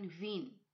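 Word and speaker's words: Wien: ইএ উচ্চারণ ই, যেমন ভিন অর্থাৎ ভিয়েনা।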